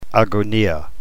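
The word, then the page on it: agonia: New Testament Greek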